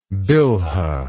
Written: bilhah

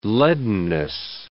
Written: leadenness

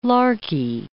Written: larky

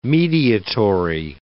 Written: mediatory

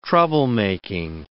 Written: troublemaking

